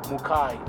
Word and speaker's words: Mukai: Mukai. M-U-K-A-I.